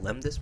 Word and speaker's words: lembas: Did I pronounce it right? Lembas bread? – Lembas.